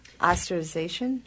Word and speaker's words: ostracization: How do you say that word? – Ostracization.